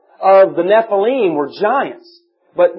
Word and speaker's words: Nephilim: Notice that what the King James does is whenever the Hebrew word Nephilim, that if you take the Hebrew and just pronounce it, it's Nephilim.